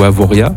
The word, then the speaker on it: Avoriaz: Au niveau mercure, il faut compter cet après-midi 17 à Avoriaz ou Avoria. – Avoria. – Avoria, merci. – On ne prononce pas le Z à la fin.